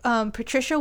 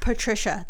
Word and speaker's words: Patricia: Like, they say Patricia with a Z in this movie. Yeah. It's not Patricia the American way. It's a very German pronunciation.